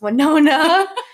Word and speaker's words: Winona: So I'm sure most of you must be thinking, wow, they have no idea how to pronounce Winona. And you're right, one of the 4 of us had no idea how to pronounce Winona Ryder, famous movie star and Joyce Byers on Stranger Things.